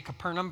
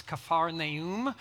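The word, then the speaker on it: Kephar Naum (כפר נחום): We always say Capernaum, but it's Kephar Naum.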